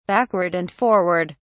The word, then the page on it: backward and forward: British English